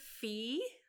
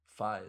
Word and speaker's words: Phee: How do you spell that? – P-H-E-E. – I was going to say, because Fi is a... – Yeah. Well, some people say Fee instead of Fi.